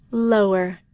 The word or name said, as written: lower